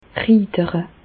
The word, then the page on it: riidere: Bas-Rhin; Reichshoffen